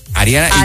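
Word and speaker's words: Hariana: Lo que pasa es que se escribe Ariana con H al principio. – Okay. Ariana H. Ajá. Ariana Mota, así. – Así de fácil. Bueno, Ariana, un abrazo y sí.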